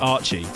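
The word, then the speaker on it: Artche: And that's a collaboration with an artist that I had no idea how to pronounce because it's spelled A-R-T-C-H-E. I found out subsequently you pronounce it Archie,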